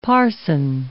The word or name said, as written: parson